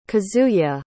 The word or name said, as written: Kazuya